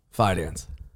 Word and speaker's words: Fidance: Fidance. – You do say the D.